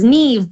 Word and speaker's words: Neve: You know, the name Neve, which, you know, we've gotten all the possible pronunciations over the years. It's not possible to mess it up in a way that we haven't heard. So yes, it's Neve, but it's actually originally a Gaelic word. I think that correct quote unquote spelling would be like even more complicated, like N-I-A-M-P-H, or, you know, all these silent letters in there.